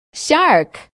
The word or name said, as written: shark